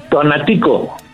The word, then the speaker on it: Tonatico: Tonatico.